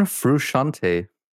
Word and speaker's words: Frusciante: John Frusciante.